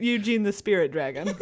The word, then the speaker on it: Ugin: I feel the need to tell him that it's pronounced Eugene, not Ugin.